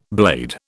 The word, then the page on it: blade: British English